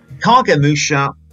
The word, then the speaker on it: Kagemusha: Now, Yuri on Patreon has given me the correct pronunciation. It's Kagemusha. Kagemusha. – Kagemusha, right, okay. – So, ge is a hard G.